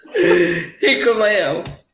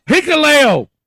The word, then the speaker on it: Hikuleo: how do you say his name, Elio? Fuck! – Hikuleo. – Hikuleo! Yes.